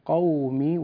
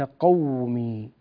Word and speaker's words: قومي: قومي، كلمة قومي، دعوت قومي وليس قومي، ليلا ونهارا، ليلا ونهارا وليس ليلا، لا تضغط على الياء.